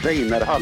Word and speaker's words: Weiner Hall: And the last name is pronounced Weiner Hall in Swedish.